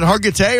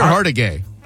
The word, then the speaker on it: Hargitay: Is it Hargitay or Hargitay? – H-A-R-G-I-T-A-Y. – I'll be damned. I've been saying Hargitay all this time. – Really? – It's Hargitay.